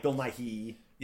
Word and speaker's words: Nighy: Not Bill Nighy. Bill Nighy. – It's pronounced the same way.